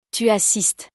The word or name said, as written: tu assistes